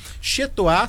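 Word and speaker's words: Chez Toi: Chetoá se escreve CHEZ e Toá se escreve TOI.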